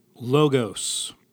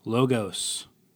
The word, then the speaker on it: Logos: Logos. Excuse me. Logos. I want to say Logos when I see that, but I believe it's supposed to be pronounced Logos.